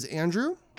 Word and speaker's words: Andrew: My first name is Andrew. – Could you spell that for me? – Absolutely. A-N-D-R...